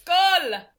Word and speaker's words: Skål: Skål. – Skål. – Skål. – Like soccer, like a goal. – It rhymes with it a little bit, like S-K-O-L or...